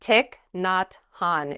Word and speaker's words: Thich Nhat Hanh: Thich Nhat Hanh is how you pronounce it.